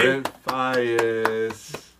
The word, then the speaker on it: Brent Faiyaz: Brent Faiyaz.